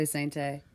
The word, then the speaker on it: Sainté: Everyone in my family pronounces our last name differently. Some people say it the way you just said it. – Sainté. – I say Sainté.